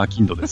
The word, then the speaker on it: 商人: 商人のアキンドですね、はい、まさにもろ読み方そのまんま